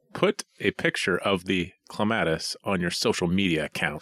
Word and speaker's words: clematis: C-L-E-M-A-T-I-S. – Clements. – Clematis. Oh, it's flower. It's flower. – A-T-I-S. Clematis.